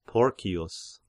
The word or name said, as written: Porcius